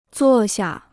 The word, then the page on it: 坐下: zuò xia